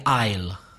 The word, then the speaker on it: aisle: You walk down the aisle. – Mm-hmm. Silent S. A-I-S-L-E. And the pronunciation is like the contraction of I will, which would be aisle. It's exactly the same. Aisle and aisle.